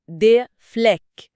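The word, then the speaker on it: Fleck: بالظبط، زي كلمة بقعة دي فليك، بتتنطق دي فليك بصوت إي قصير وسريع وبعده ك واضحة.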